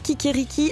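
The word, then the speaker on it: Kikeriki: Kikiriki, le podcast, ça s'écrit K-I-K-E-R-I-K-I. E il y a une petite feinte.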